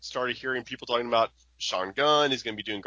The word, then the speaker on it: Sean Gunn: It's Sean Gunn.